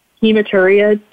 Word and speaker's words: Hematuria: So it's the Royal Canin Hematuria Detector. Did I say that right? – Yeah, you did.